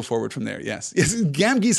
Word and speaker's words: Gamgee: My audiobook pronounces the second G in Gamgee the same as the first.